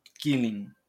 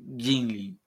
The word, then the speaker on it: Gimli: E o, o John Rice Davis, que ele é o anão Killing, Gimli.